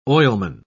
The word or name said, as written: oilman